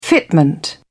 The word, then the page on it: fitment: British English